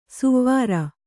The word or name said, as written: ಸುವಾರ